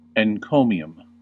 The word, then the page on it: encomium: American English